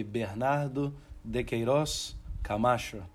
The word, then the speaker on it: Bernardo de Queiroz Camacho: So Bernado, in the Portuguese accent, would be Bernardo de Queiroz Camacho. Yeah, which sounds a little bit more exotic than Bernardo de Queiroz Camacho.